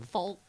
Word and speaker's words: folk: I would not pronounce the L. I would say folk.